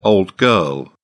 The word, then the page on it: old girl: British English